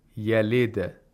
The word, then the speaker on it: yalid: There is also a qalqala in the word yalid, and it's important that you apply this qalqala.